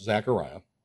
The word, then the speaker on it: Zacharias: There was in the days of Herod, the king of Judea, a certain priest named Zacharias. Also, the name could be pronounced Zachariah, of the division of Abijah.